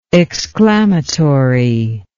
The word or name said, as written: exclamatorily